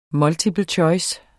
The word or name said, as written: multiple choice